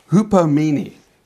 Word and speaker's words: hupomene: This is, it's a made up of a Greek word, and I'm going to try and pronounce this Greek word. It's called hupomene. Hupomene, and it literally translated could be hang in there power.